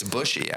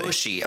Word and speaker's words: Bushy: Think of like a bushy bush, like a bush, bushy.